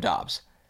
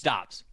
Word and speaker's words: Doubs: And then I listen to the broadcast of the plays he's made in the preseason, and they're calling him Dobbs. – Okay, then it's Dobbs. Then who knows? It was Dobbs.